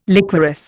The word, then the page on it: liquorice: Indian